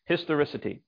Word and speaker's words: historicity: And this is a word I only recently found out that I was mispronouncing for my entire adult life. So thanks to spell check. But there's historicity.